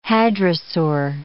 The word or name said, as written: hadrosaur